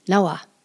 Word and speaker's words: nawa: They had nawa. If you were to spell it, it would be N-A-W-A. The Pawnee short A says like the English A in above, about, what, was, the sound. In pre-contact times, it was pronounced rawa, spelled R-A-W-A, and you'll still see this pronunciation in some of the older dictionaries.